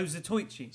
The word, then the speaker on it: Zatoichi: Oh, Zatoichi.